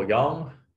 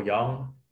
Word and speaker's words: Yeung: Search Michael Yeung or / Michael Yeung. So my last name is spelled Yeung. So yeah, either search out Michael Yeung